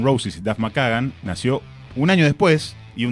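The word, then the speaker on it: McKagan: sí, tranqui, se dice así, es McCagan.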